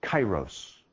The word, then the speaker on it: kairos: This word's pronounced chronos. This one is kairos.